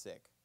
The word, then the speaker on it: Sikh: It's not, people mispronounce it as Sikh. It's actually pronounced Sikh, Sikhism.